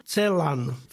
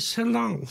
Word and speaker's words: Celan: Ancel, celan. Francúzi ho vyslovovali celan a Paul, hej. A ja vždy každému hovorím, pozor, to je Paul Celan. Tak toho musíte vysloviť.